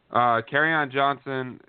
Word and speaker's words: Kerryon Johnson: I haven't watched Kerryon Johnson, and I don't even know if I pronounced it right. What are your thoughts on the player? – Kerryon Johnson, you did pronounce it right.